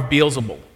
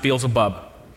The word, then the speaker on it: Beelzebul: He's just casting out demons by the power of Beelzebul, sometimes pronounced Beelzebub.